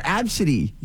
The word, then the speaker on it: ABCDE: Which is A-B-C-D-E, but it's pronounced Ab-sa. D. – Oh, I've heard of that before. Absidy. Yes. – Absidy.